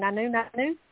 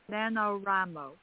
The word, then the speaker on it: NaNoWriMo: Nanu? – NaNoWriMo.